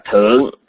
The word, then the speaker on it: thượng: Thì trong tiếng Hán đó cũng một chữ đó thôi, nhưng mà hết nghĩa trạng từ họ đọc là thượng, có nghĩa là ở trên.